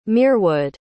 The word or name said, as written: merewood